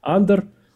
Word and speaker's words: Андор: Правильное ударение, насколько я понимаю, все-таки «Андор».